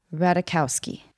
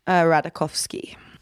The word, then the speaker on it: Ratajkowski: Okay, Emily Ratajkowski. – Yes, that was right. – Oh, thanks. Michael Sherman told me to not pronounce the J.